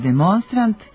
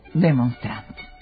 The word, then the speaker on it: demonstrant: Akcenat u srpskom jeziku ne može da stoji na poslednjem slogu u reči, tako da se u rečima stranog porekla sa završetkom na ant, ont i ent mesto akcenta pomera za jedan slog unapred i od kratkosilaznog postaje kratkouzlazni, a od protestanta i demonstranta postaju protestant i demonstrant.